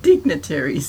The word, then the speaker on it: dignitaries: As elder digitaries, digitaries, so you pronounce it? Dignitaries. Dignitaries.